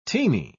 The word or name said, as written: teeny